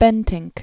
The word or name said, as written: Bentinck